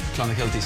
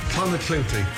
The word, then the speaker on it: Clonakilty: Irish tea bags or Clonakilty sausages from Cork. – Clonakilty, thank you.